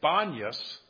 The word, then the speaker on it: Banias: You know, you might notice I'm pronouncing it Banias because Arabs can't pronounce the P.